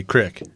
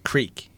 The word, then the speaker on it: creek: I'm on a border town there in South Dakota, so I do say crick, and that's not the popular. Creek is how it's said.